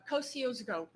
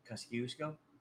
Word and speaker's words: Kosciuszko: Kosciuszko Bridge in Brooklyn. It's like just underneath the Long Island Expressway. What? – Kosciuszko? – Is that what it's called? Mm-hmm. Okay. See, I pronounced it wrong.